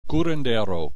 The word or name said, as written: curandero